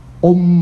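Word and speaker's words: 唵: 啊，是八，一个口一个八，Om Mani，八妹吼，你也不能念安哦。那其实那个安不是念安，那安是念 om 的意思，英文是 om 发音的 om om mani padme hum 或者叫 padme hum。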